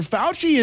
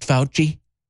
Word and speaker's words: Fauci: I'll expose you next, Fauci. You know what Fauci rhymes with? Fauci, Nazi. Nazi, Fauci.